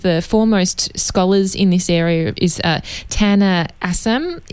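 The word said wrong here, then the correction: Akçam: Is that how to pronounce his name? – Acham.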